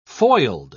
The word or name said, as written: foiled